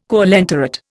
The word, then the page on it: coelenterate: Indian